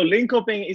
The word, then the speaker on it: Linköping: But at the same time, we have some news to discuss, and I'm glad I have my Swedish pronunciation guide with me, Linköping. Did I mess that up? The team in Sweden? – No, Linköping is actually correct in the North American side to say it, but here in Sweden we say Linköping.